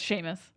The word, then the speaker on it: Seamus: Oh, is that how you pronounce it? – How's it spelled? – Seamus. It's spelled like Seamus. Oh, yeah, it's Seamus. – Oh, Seamus. Okay. – I know that because of Harry Potter.